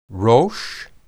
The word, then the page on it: rosh: Hebrew